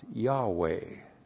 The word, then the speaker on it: Yahweh: Now, if you actually pronounce that in Hebrew, it would be pronounced Yahweh.